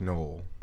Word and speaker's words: Noel: Why is Noel's name not pronounced Noel? – It's because Noel is French, and that's how it's pronounced in French.